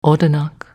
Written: Odanak

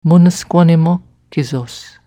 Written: Moonesquannimock kesos

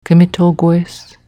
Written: Kmitogwes

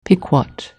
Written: Pequot

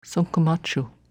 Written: Sunkkmachue

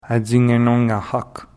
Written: Attigneenongnahac

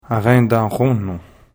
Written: Arendahronon

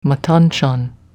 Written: Matanchan

